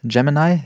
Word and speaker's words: Gemini: Wo du gerade schon über Gemini, so spricht man es auch genau gesprochen hast, KI und Content-Kreation ist wahrscheinlich auch ein großes Thema.